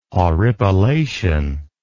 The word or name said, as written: horripilation